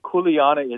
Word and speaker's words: kuleana: The kuleana? Is that how you say it? – Yeah, in Hawaii, it's kuleana is more than responsibility.